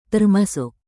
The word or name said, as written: termasuk